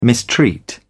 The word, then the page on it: mistreat: British English